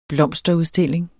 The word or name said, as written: blomsterudstilling